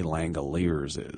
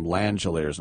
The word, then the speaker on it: Langelier: Langeliers is. It's a hard name to pronounce. It's like J.D. was calling him Langeliers, and I don't blame him. It's actually Langelier.